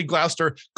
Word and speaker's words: Gloucester: Well, Stephen Hample, Pastor Steve, is pastor at, help me say it right, I want to say Gloucester.